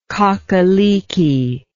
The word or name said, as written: cockaleekie